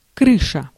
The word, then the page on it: toit: France; IPA: /twa/